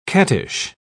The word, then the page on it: cattish: British English